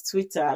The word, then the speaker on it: TWTTR: The original name was Twitter. Like it's pronounced Twitter, but it was just TWTTR.